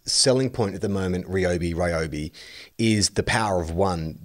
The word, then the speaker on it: Ryobi: Ryobi is how it's pronounced. Mike has put that up on the little message window. And it says, it's Ryobi, source, I can read Japanese.